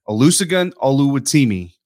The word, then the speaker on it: Olusegun Oluwatimi: a loose. I already messed it up. Olusegun Oluwatimi.